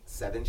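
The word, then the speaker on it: Sevyn: Liquid Courage by Savin Streeter. – Wait, by who? – Seven Streeter. – Seven, sorry. Why did I say her name like that? – Seven.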